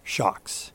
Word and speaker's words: Shokz: By the way, it's spelled S-H-O-K-Z, Shox.